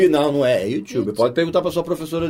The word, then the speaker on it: YouTube: E é YouTube a pronúncia. – Agora já veio de sacanear. – Não, sempre, porque as pessoas aqui, elas costumam brasileirar as palavras.